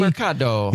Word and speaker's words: Demercado: I think what you have to do Demarc- is pronounce it in a very Italian way. Demercado.